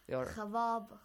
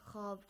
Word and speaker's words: خواب: In Persian, like khawab, khab, khab. Yeah, yeah, yeah. Good, good, good. In Persian as well, we have khawab. It's written, but it's pronounced khab. – Waw is silent.